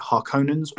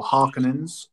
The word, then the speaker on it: Harkonnens: The current leaders of Arrakis are the Harkonnens or Harkonnens. Both pronunciation is acceptable if you watch the 1984 original.